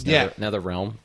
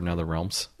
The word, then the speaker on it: NetherRealm: It's NetherRealm, but you're NetherRealms.